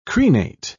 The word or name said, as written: crenate